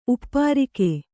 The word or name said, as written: ಉಪ್ಪರಿಕೆ